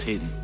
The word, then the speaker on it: HDN: Yeah, it's he spells it HDN, but it's pronounced Hidden.